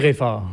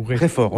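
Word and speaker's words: Reford: On dit Réfort. Ou Réfort, on ne dit pas le « de ».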